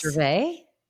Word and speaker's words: Gervais: Do you pronounce the S? – Yes. – Gervais. Okay. Michael Gervais.